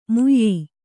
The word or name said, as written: ಮುಯ್ಯೀ